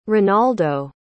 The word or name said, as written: renaldo